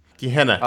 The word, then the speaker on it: Gehenna: The hordes of Guiana... I can't pronounce it. The point is... – Guiana? – Gehenna. Oh. Gehenna. Gehenna. – Gehenna. Yeah, like the Gehenna Stone.